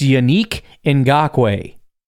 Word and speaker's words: Yannick Ngakoue: Some people call him Yannick because they're idiot Packer fans. His name is pronounced Yannick Ngakoue.